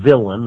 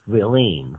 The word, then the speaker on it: villein: We call them villain, that's the English pronunciation. It's spelled V-I-L-L-E-I-N, it's French. Villeine, because wow.